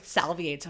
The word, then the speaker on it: salivate: Wait, how do I say it then? – Salivate. – Salivate. – That's it.